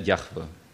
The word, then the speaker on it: Jahwe: Ausgesprochen, nach den Rekonstruktionen, die wir haben, wird er Jahwe.